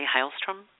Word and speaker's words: Heilstrom: Did I say that correctly, Heilstrom? – Yes. He's German, so the German word for healing stream is Heilstrom.